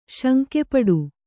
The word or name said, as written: ಶಂಕೆಪಡು